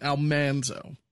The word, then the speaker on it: Almanzo: And we were talking about the pronunciation of Almanzo versus Almanzo. – Yes. – And she was saying that it's like the big thing. I brought this up to Dean Butler as well. Dean Butler goes 100% Almanzo. He's like, that's the way it was pronounced.